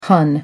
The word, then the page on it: hun: American English